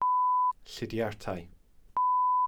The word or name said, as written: llidiartau